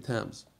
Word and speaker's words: Thames: But if you could find a river like the Thames, is that how you pronounce it? I don't know. You English people, you know.